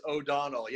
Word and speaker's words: O'Donnell: It is O'Donnell. Yeah, not McDonald or O'Donnell. It's okay. Michael O'Donnell.